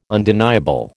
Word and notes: undeniable: American English

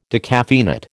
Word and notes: decaffeinate: American English

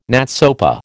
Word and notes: NATSOPA: American English

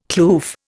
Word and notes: kloof: Indian

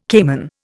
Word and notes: caiman: Indian

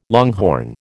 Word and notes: longhorn: American English

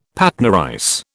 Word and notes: Patna rice: British English

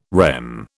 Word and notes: REM: British English

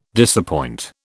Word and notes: disappoint: British English

